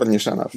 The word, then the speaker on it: Nishanov: Am I getting your name right? Nishanov? – Yes, Gore. Yes, Gore Nishanov.